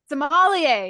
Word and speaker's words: sommelier: So we find out that Fern has been putting in 12-hour days now that they're, how do you say it? – Sommelier. – Sommelier. Yes, there you go.